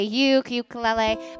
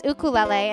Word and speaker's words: ukulele: And that is how you pronounce it, ukulele. I know people say ukulele, but it's ukulele.